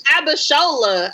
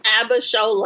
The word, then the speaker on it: Abishola: No, it's Abishola. Oh. I can't stand you. Cannot stand you. – You knew what I was talking about. – Yeah, after like 5 minutes. – How you say it? – Abishola.